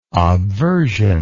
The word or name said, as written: obversion